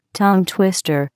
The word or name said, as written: tongue twister